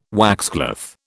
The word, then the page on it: waxcloth: British English